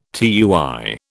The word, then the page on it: tui: British English